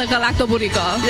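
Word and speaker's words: Galaktoboureko: And she said, if you say garlic bread to go really quickly, like garlic bread to go, it sounds very similar to the Galactoburico.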